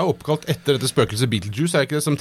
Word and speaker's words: Betelgeuse: Og da sier han, aller først, hvordan uttales navnet på stjerna? Det har jeg da svart på, det Betelgeuse. Det hvert fall sånn vi vanligvis gjør det i Norge.